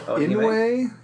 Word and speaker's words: Inoue: Orihime Inoue.